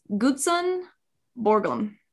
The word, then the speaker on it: Gutzon Borglum: And I did have to look up how to pronounce his name online. And it's Gutzon Borglum.